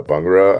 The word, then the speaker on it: bhangra: Like I love bung- uh, bhangra, I believe is how it's pronounced.